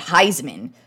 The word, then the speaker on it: Highsman: And I was also reading about Ricky Williams has a new line of cannabis as well called Heisman, spelled H-I-G-H-S-M-A-N, like Heisman instead of spelled like the Heisman Trophy because, you know, he won a Heisman Trophy, so I think that's a perfect name.